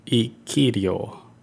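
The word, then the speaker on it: Ikiryō: I think it's pronounced Iriku. – Iriku. – Ikirio. – Oh, Ikirio. – Ikirio. It almost sounds like Ikirio.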